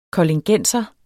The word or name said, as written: koldingenser